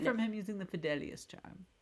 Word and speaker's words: Fidelius: Fidelius. – I'm sorry, how did I say it? – Fidelius. – Fidelius, sorry.